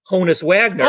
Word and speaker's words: Honus Wagner: Yet the card that was always the most, not ridiculous, but always had the highest price was Honus Wagner.